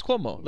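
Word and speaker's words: Como: Wait, am I pronouncing that wrong because of the Cuomo brothers? – No. You're right. It's Cuomo. Lake Cuomo.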